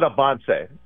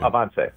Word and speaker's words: Avance: We call it Avance, which literally means advance in Spanish. – Gotcha. – So, Avance.